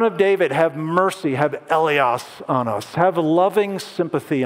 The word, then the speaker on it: eleos: And by far, the most common one is this top word, and that's pronounced eleos.